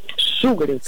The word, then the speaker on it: шугаринг: Поэтому ударение в нем нужно ставить на первый слог и говорить правильно еще раз. Как? – Шугаринг.